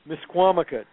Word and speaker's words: Misquamicut: Is it pronounced Miskamakut? – Misquamicut, yes.